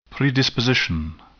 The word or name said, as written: predisposition